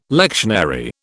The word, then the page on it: lectionary: British English